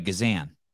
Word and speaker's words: Gazan: I said it right, Gazan.